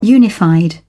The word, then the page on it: unified: British English